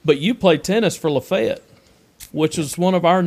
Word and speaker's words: Lafayette: And when I was at Ole Miss, I worked at a church out in the county, and I pronounced Lafayette. – Mm-hmm. – And man, they corrected me quick. They're like, "You are wrong." – That's Lafayette, Louisiana.